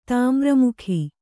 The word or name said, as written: ತಾಮ್ರಮುಖಿ